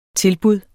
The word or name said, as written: tilbud